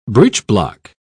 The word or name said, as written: breechblock